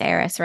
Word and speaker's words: Eris: It's pronounced the same. Because when I think of Eris, I do also think of like the Greek goddess Eris.